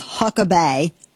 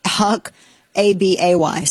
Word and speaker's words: Huckabay: Yeah, so the guy's name is Huck Abay. Now, I'm not saying it like Britney Spears. It's spelled like Huck, A-B-A-Y. So not like Huck A-B-E-E. It's Huck Abay, H-U-C-K-A-B-A-Y.